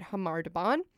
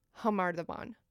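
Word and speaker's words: Khamar-Daban: you might be confused about why we're pronouncing it Hamar Daban. The most popular spelling is actually spelled K-A-M-A-R Daban. – Mm. – And then the second most popular is C-H-A-M-A-R Daban. So when you're listening to English-speaking people talk about this story, a lot of them will say Kamar Daban or mm-hmm. Chamar Daban. – Mm. – But as we just heard from the song, it's pronounced Hamar Daban. – Right. – Like an H noise.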